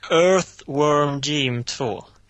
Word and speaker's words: Earthworm Jim: Earthworm Jim 2. – Vilken del är svårt? Är TH-ljudet och W-ljudet efter varandra? – Mm. Det är som Earth.